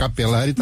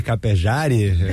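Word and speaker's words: Capellari: Mas dois L's em espanhol se pronuncia como J, né? Não tem como. Chama de capejari? – Não tem essa. Mas nesse caso é italiano, né? – Italiano. – Esse caso é o italiano.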